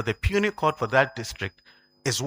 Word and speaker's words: puisne: It's spelled P-U-I-S-N-E, but it is not pronounced Pusni. It's pronounced puny.